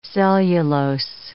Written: cellulose

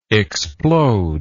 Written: explodable

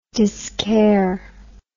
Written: discaire

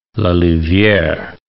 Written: La Louviere

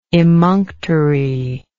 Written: emunctory

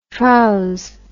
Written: trouse

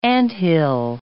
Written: anthill

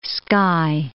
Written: Skye

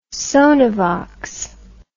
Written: sonovox

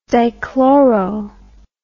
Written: dichloro

